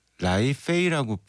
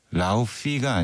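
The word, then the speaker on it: Laufey: 1053님, 야, 이게 라이페이라고 발음을 해야 돼요? 하긴 또 아이슬란드니까 라우피가 아니라 라이페이라고 황정현님이.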